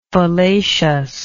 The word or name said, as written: fallaciousness